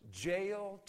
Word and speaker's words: Jael: It rhymes. Jael took a nail And she went softly unto Sisera.